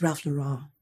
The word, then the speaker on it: Ralph Lauren: It's Ralph Lauren. – Is that how you say it? – Yeah.